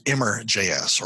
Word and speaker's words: Immer: And it's called, I pronounce it Immer.js or Immer, Immer.js.